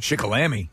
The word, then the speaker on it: Shikellamy: Seniors at Shikellamy High School. – Shikellamy. – Or Shikellamy. I'm not sure how you pronounce it. – Oh, you said it right the first time.